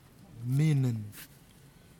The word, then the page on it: minin: kwamera